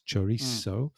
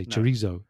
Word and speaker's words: chorizo: With a Z sound, which is not correct. So they say chorizo, chorizo would be there.